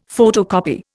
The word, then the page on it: photocopy: Indian